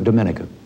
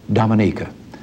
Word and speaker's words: Dominica: Prime Minister Eugenia Charles of Dominica. I call that wrong. She pronounces it Dominica.